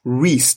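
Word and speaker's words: Reyes: And our story begins with a guy called Reese Daniel Ruiz. I hope I'm pronouncing his first name right. Reese. That's R-E-Y-E-S.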